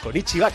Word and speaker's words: Konnichiwa: Konnichiwa. – Oye, Konnichiwa, que le gusta decir y se dice así.